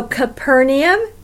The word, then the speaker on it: Capernaum: It is also interesting that the town we now know as Capernaum really is Capernahum. That's what it means. The town of Nahum. So Capernaum was his town. It was named after him. It actually should be pronounced Capernaum. We roll everything together in English and call it Capernaum.